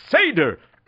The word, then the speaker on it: Seder: No, it's pronounced Seder.